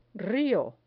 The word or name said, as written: rio